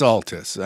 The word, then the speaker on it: Altes: It's Altes. It's not Altes. What do you guys prefer? – Well, it's Altes. I mean, that's how they Altes. Pronounce it. It just means old in German. – So oh. – German people would person would say Altes.